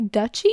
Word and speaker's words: duchy: how do you say that? D-U-C-H-Y? Duchy? – Duchy.